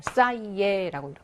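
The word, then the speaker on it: ça y est: 싸이에라고 이렇게 쓰이에 안 하고요. 싸이에 이런 식으로 바꿔줍니다.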